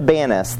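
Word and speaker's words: Banias: Indeed, welcome to Banus, the Arab pronunciation for Pan." Arabic has no P sound, so it moves over to a B sound.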